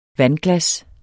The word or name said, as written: vandglas